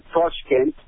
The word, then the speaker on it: Tashkent: It's pronounced as Tashkent, and it is the biggest city in Central Asia.